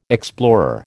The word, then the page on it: explorer: American English